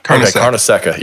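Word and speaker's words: Carnesecca: Karnaseca.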